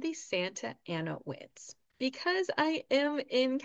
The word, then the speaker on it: Santa Ana: If you are a Spanish speaker, you are going to want to say Santa Ana.